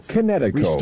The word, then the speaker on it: Kinetico: Everybody knows that Connecticut is spelled K-I-N-E-T-I-C-O. You should have remembered Kinetic as in kinetic energy with an O on the end. Connecticut. – Spell it K-I-N-E-T-I-C-O.